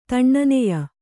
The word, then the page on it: ತಣ್ಣನೆಯ: taṇṇaneya